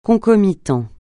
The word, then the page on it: concomitant: francais